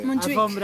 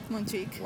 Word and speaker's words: Montjuïc: Montjuïc.